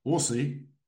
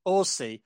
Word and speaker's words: Orsi: How is it pronounced? – Orsi. – Orsi. – Orsi, as in horsey without the H.